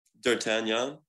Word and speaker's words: D'Artagnan: I don't know how to pronounce this. Art Agnan? – D'Artagnan? – Yeah, sure. Let's go with that.